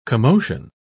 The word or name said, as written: commotion